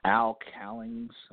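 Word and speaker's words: Al Cowlings: And, you know, it was Al Cowings.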